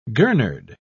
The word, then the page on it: gurnard: British English